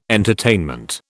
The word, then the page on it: entertainment: British English